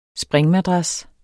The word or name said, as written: springmadras